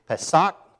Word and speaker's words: Pesach: But you spell it P-E-S-A-C-H, which doesn't sound like Pesach at all, but that's just the way it is. And if you don't say it right, it's say Pesach. Can you do that? It's guttural. It's a guttural language, and so...